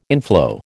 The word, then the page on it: inflow: American English